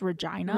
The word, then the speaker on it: Regina: It's pronounced Regina.